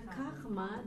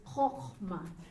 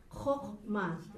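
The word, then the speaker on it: kochma (חכמה): This is not kachma. This is kochma. This is the O sound.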